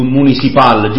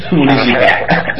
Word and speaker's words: Municipal: Allora, tu il nome del gruppo in effetti si dovrebbe pronunciare alla francese, quindi dovrei dire più Municipal, giusto? – Sì, la Municipal. Infatti io invece l'ho pronunciato con la C, quella di quella alla tedesca, proprio, cioè Municipal.